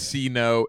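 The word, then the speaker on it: Sea-No: For the Christmas album, his name is C-No. It's spelled S-E-A hyphen no.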